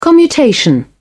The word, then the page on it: commutation: British English